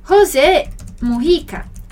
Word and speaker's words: Mujica: Então, l'ex-presidente dell'Uruguay é José Mujica. Agora, essa é a pronúncia no espanhol, né? O J no espanhol tem esse som aí aspirado, né? Parecido com o nosso R.